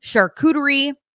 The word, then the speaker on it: charcuterie: That event features complimentary hot cocoa, charcuterie. I think I said that right. Yes, nailed it first time.